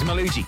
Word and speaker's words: Malugi: His name is Malugi. If you want to go look him up, it's spelled M-A-L-U-G-I.